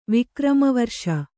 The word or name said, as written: ವಿಕ್ರಮವರ್ಷ